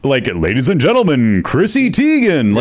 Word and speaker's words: Teigen: Chrissy Teigen.